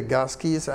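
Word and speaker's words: GASCI: Of course, you will set your, you can go to the gas keys, and we say gas key with a hard C rather than a soft one.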